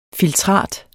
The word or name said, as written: filtrat